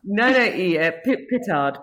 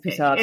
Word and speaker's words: Pittard: It's technically like French origin. So if you really want to go fancy, then you can put a bit of a French accent on it, but it's fine. – Well, let me hear it. Let's not hear the Indiana accent. What's yours? How do you say it? – It's, oh gosh, the pressure. So it's kind of got the roll. So it's like petard.